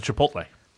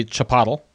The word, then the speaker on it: Chipotle: Okay, so here's the Chipotle. Probably my biggest pet peeve in terms of words is when people mispronounce it. Yeah. – Chipotle, like Chipotle. Chipotle. Yeah, I can't stand it. It's not Chipotle.